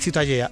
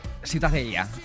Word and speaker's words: Ciutadella: ¿cómo se pronuncia? – La Ciutadella. – Ciutadella. Bueno, pues Ciudadela también en castellano. – Ciutadella, sí. – Pues eso, ha quedado clarísimo. Pero, ¿para qué se meten? – ¿Tú cómo lo pronuncias? – Ciutadella.